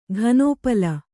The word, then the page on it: ಘನೋಪಲ: ghanōpala